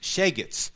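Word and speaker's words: shegetz (שייגעץ): Colloquially, a little devil, and sometimes a term of endearment for a naughty child, and it's pronounced shegetz, literally, or in literary terms in Yiddish. Or a non-Jewish boy can be called a shegetz. They turn the K sound into a G sort of sound.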